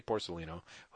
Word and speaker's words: Porcellino: Porcolino. Porcellino? I always say Porcellino. Hope that's the way I, it's pronounced. I think you're right.